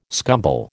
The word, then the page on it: scumble: American English